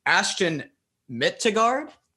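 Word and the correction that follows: Åsbjørn Midtgaard: Asborn Mittegard. – Oh, gosh. Asborn Mittegard, 7 foot 270.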